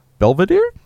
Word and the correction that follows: Bedivere: Is that Bedivere? – Yep, Bedivere.